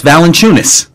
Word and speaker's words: Valanciunas: You and Doris Burke can't pronounce that name. I've said it twice, Valanciunas.